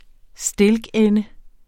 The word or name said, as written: stilkende